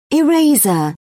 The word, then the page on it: eraser: British English